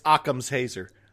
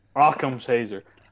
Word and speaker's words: Occam's Hazer: It's Occam's Hazer. – Occam's Hazer. – As in Occam's Razor? – Whoop-dee-doo.